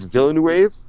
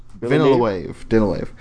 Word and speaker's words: Villeneuve: Denis Villeneuve? – I think it's Villeneuve. – Villeneuve. – I don't know if North Americans can truly pronounce it properly.